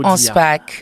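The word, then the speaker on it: Anspach: Est-ce que je le dis comme il faut dire? – Boulevard Hans Pasch.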